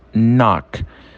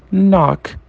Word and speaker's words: knock: Segunda palavra nós temos knock. Knock é bater, tá? Repara que é quase a pronúncia é quase sem o K.